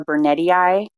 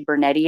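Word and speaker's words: burnetii: Well, at first I was pronouncing it Coxiella burnetti because it has two I's at the end. So I wasn't sure how to pronounce that. But then I heard someone else say burnetti and I was like, oh, okay. I'm going to start saying it like that.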